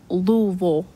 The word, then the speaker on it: Louisville: Anything that has a ville at the end, they say vol, like Louisville, Kentucky. It looks like Louisville or Louisville, but Louisville.